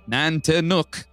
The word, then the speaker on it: Nantiinaq: Nantinaq pronounced Nantinaq. It's right there. Nantinaq. Yeah. – That's nuck, not nook.